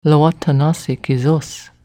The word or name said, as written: Lowatannassi kesos